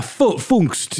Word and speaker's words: Pfungst: Oscar Pfungst? – Yeah, Pfungst. Yeah, the P is silent. P-F-U-N-G-S-T. Oscar Pfungst. I did kind of make a P sound there because it's fun.